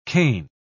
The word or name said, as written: kain